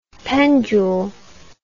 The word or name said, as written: pendule